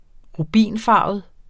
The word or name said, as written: rubinfarvet